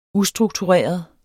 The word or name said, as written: ustruktureret